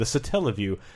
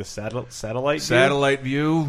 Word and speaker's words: Satellaview: The Satellaview, which I think I didn't know how to pronounce until a VG Empire where it came up, and I was like the satellite view. The Satellaview.